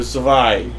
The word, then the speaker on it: Zwei: By the way, we saw some toy review videos of it, and it's not called the Buggle Driver 2. It's pronounced the Buggle Driver Zwei because it's the German number 2.